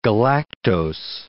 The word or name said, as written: galactose